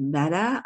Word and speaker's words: Metta: And I know it's called Metta. I think I'm saying that correctly. – That's correct.